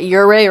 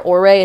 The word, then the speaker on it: Ouray: But Ouray or Ouray, it's like O-U-R-A-Y.